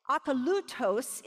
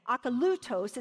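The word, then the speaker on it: Akalutos (ἀκωλύτως): Akalutos is the way you'd say it. Akalutos is going to have the accent on the third syllable.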